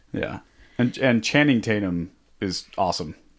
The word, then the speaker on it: Channing: It's Channing.